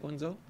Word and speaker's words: Guangzhou: Is it Guangzhou? – Guangzhou.